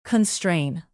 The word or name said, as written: constrain